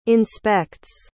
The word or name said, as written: inspects